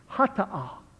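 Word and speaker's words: Hata'ah (חטאה): Now, in the Hebrew worship custom, there is what's called the, let me spell it for you first, the C-H-A-T-A-A-H, Hata'ah, Hata'ah. The Hata'ah means the sin offering.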